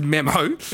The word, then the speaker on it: Memmo: It's pronounced Memmo. – Yeah. – You can log on to Memmo and pay $21, and you're going to get a message from Flanners.